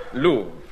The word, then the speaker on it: Louvre: Stolen from the, how do you pronounce this? – Louvre. L-O-U-V-R-E, pronounced Louvre.